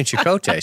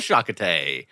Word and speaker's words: Chakotay: Okay, so it is definitely Chakotay, so I just want to help you out.